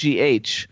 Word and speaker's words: Hough: But if remember that Hough is Hough. And not Huff.